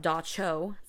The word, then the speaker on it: Dubtsog: Among these evil spirits assumed to have been left behind was the nocturnal pressing demon, pronounced Dacho, spelled D-U-B-T-S-O-G.